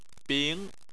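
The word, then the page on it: bing: tone 2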